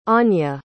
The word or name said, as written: aine